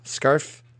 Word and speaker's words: Scarfe: Do you know, am I pronouncing that correctly? Do you know? Scarfe? – That sounds right.